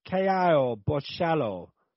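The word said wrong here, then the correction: Borralho: I can't remember how to say his name. Say his name, Dan. – It's Kai Bohio.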